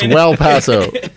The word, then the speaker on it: Well Paso: And, it's Well Paso. – There's a difference. You know? – It rhymes with El Paso.